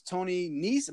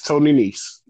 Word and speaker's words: Nese: Next match, Cruiserweight title, Drew Gulak versus Tony Nese. Am I saying his name right? – Yes, Tony Nese. – Tony Nese, right.